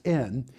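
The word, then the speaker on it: in (ἐν): And the word in Greek is the word ian, and it's pronounced in.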